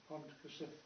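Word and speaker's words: Pontcysyllte: Can you pronounce it? – Pontcysylltau. – Thank you. That's how it's pronounced.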